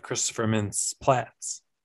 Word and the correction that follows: Plasse: I think it's Plasse. – Oh, have I been saying it wrong this whole time?